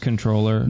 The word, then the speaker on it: comptroller: Now, when we say he's the controller, are we doing that weird government thing where the word is comptroller, but it's pronounced controller? – No.